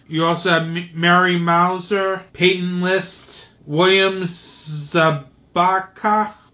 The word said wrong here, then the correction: Zabka: William Zabka. Yeah. Zabka. William Zabka.